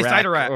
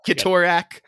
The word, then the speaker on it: Cyttorak: I say Cyttorak. Or... I say Cyttorak. oh, okay. Yeah. yeah, yeah. K- well, Kitorak. Oh, no, it's definitely a soft C.